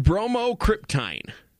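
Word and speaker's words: bromocriptine: Bromocryptine. – Bromocryptine. – It is a polypeptide alkaloid that is a derivative of ergot and mimics the activity of dopamine. – Oh, yeah. – Bromocryptine. – Bromocryptine.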